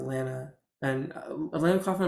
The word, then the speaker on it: Alana McLaughlin: And it's tough because while Fallon Fox and Alana McLaughlin, am I saying her name right? – I believe so, yes.